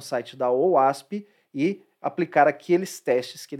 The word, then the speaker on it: OWASP: Como ela é uma aplicação web, é, vale lembrar que existe uma comunidade aberta chamada OWASP. OWASP se escreve O-W-A-S-P e essa OWASP tem uma série de recomendações de segurança para aplicações web.